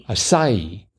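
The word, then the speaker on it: açaí: I think the stress is on the middle syllable. – Acai.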